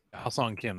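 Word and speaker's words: Ha-Seong Kim: I think it might be Ha-Sung Kim. – Yeah. – Is that? I can never get his name right. I gotta look it up now. – I'm pretty sure it is. – Ha-Sung Kim, that's the right one.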